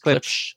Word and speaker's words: Klipsch: Klipsch.